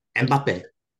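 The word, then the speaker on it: Mbappé: Mbappe is such a diva, bro. Let's be real. – Bro. Say his name right, please. Mbappe.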